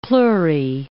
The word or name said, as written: pleurae